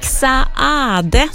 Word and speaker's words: Saade: Erik Saade, så har jag ju lärt mig att man ska uttala en sann, han stavar ju S-A-A-D-E, och då ska man tydligen uttala båda A-N också, så då gör jag det.